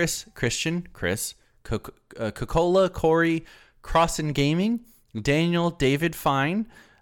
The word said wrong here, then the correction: Finney: or Finney, sorry, I just pronounced your entire name,